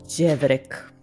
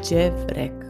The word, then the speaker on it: Đevrek: Đevrek. Đevrek. Э, произношение голоса «дж» в принципе похоже на произношение этого мягкого нашего «ч», которое у нас было раньше.